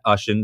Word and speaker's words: Ashan: I almost forgot how to say my own name. So at Ashan on Instagram, Twitter, at Ashan, depending on how you say it, wherever you're from. That's A-S-H-A-N.